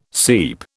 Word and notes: seep: British English

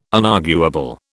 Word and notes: unarguable: British English

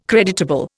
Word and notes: creditable: Indian

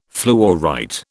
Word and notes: fluorite: British English

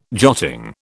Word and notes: jotting: British English

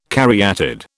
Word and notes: caryatid: British English

